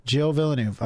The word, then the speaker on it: Gilles Villeneuve: Gilles Villeneuve. Okay. – Yeah. – So they it's Jacques, I want to say his uncle. – Gotcha. – The late Gilles Villeneuve.